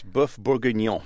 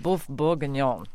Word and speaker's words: bœuf bourguignon: It's bœuf bourguignon. – Oh, bœuf bourguignon.